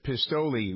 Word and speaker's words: Pistole: This Pistoli, which is that's the Italian pronunciation of pistol, his name is spelled P-I-S-T-O-L-E, acknowledging that this incident in San Diego,